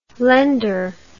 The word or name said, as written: laender